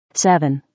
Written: 7

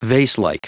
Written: vaselike

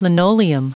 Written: linoleum

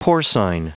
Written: porcine

